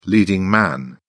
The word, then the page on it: leading man: British English